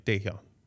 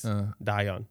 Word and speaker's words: Dahyun: Like there's a girl there that's named, you spell it D-A-H-Y-U-N. – D-A-H-Y-U-N. Y-E-N? – U-N. – U-N? – Mm-hmm. – Da-yeon? – Yeah, like you would say like Daehyun. – Daehyun. – Daehyun. – Oh, okay. But that was pretty close. – But like how we would say it, how it's spelled, but it's Da-yeon.